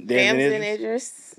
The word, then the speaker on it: Damson Idris: Damson Idris.